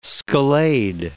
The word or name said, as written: scalade